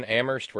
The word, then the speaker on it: Amherst: And that would mean saying thank you to our buddy Reggie and to Rocco and to Elizabeth, to Joseph, to Terry in Amherst, where only the H is silent,